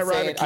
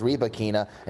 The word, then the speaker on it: Rybakina: So I got Elena Rybakina. – I've been saying Rybakina's name wrong the whole time. I used to think it was Rybakina, and now everybody says Rybakina. So it's Rybakina.